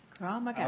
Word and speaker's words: Krav Maga: Is a certified instructor with a technique of Israeli technique of self-defense called, is it crawl? You tell me how to pronounce it, because it's not. – Krav Maga.